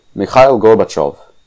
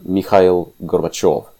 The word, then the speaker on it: Mikhail Gorbachev: Mikhail Gorbachev, that's how you pronounce his name, by the way. Mikhail Gorbachev. The stress is on the last vowel.